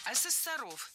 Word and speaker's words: ассессоров: Ассессоров. Неверно. Правильно, ассессоров. Существительное «ассессор» имеет неподвижное ударение на втором слоге. Ну а раз оно неподвижное, значит мы его не двигаем при изменении слова. Ассессоров, ассессорами, ассессором.